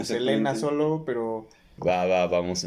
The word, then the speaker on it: Selena: Su pronunciación correcta es Selena.